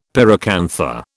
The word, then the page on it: pyracantha: British English